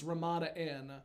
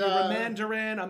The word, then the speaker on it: Ramada Inn: one of the consistent gags was that they would mispronounce Ramada Inn. – Yeah, because it would be Ramandarin, Ramandarin. – Yes.